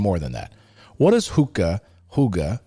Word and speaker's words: Hygge: The Danish word Hygge, pronounced Hygge. Okay. It's pronounced Hygge.